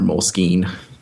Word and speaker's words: Moleskine: So I think they've actually allowed, if you will, the official pronunciation can be moleskin or moleskine.